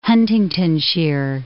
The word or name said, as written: Huntingdonshire